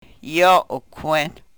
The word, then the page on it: yo u-kwen: Yurok